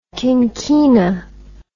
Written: quinquina

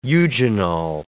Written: eugenol

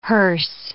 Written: hearse

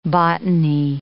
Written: botany

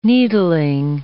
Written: needling

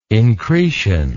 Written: incretion

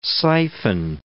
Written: siphon

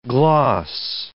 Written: gloss

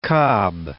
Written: Cobb

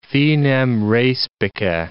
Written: finem respice

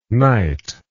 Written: nite

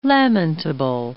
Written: lamentable

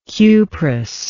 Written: cuprous

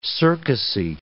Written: circusy

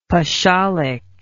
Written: pachalic

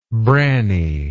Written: branny

